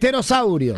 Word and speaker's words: pterosaurio: El pterosaurio se escribe, empieza con la P. Claro, con la P. – Pero no se pronuncia.